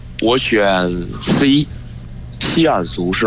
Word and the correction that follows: 锡伯族: 是吗？ – 锡伯族。